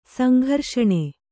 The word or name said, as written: ಸಂಘರ್ಷಣೆ